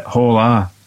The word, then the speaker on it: hola: Wider than the nation, for our international listeners out there, hola. That's how you say hello in Spanish. – Nice. – You don't really pronounce the H, but...